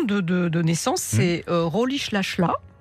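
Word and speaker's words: Rolihlahla: Alors on prononce comme ça. Parce que nous, on l'a prononcé Rolilala. – Oui, mais c'est parce que j'ai un peu répété l'accent Xhosa, qui est donc l'ethnie de Rolish Lashla.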